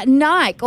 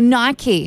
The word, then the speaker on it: Nike: Nike or Nike, however you like to say it. Americans say Nike, and we used to say Nike, but I've noticed it now, Johnny, we're saying Nike a little bit more.